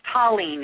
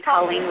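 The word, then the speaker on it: Taline: It's Tallene. It's actually Tallene with a T. – Spell it for me. – It's T-A-L-I-N-E.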